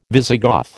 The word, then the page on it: Visigoth: American English